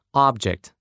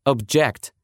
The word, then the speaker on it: object: И там ударение будет на первом слоге. Several people reported seeing a strange object in the sky. Несколько человек рассказали, что видели странный объект в небе. А вот если мы перенесем, как в русском, ударение на второй слог, получится глагол object.